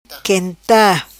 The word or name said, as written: quenta